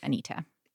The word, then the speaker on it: Anita: The backstory, my mom is British, and that is why I pronounce it Anita.